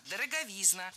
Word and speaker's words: дороговизна: Дороговизна, дороговизны – это неверно. Правильно, дороговизна. Ударение в этом слове падает на суффикс «изн». Во всех формах там и остается.